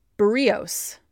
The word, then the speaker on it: Berríos: Wednesday, Jose Berríos. I said that right this time, against Gerrit Cole.